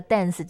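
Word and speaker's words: DNCE: 就像这个 dance 这个词去掉了 A，那这个 dance 还是可以发音哦。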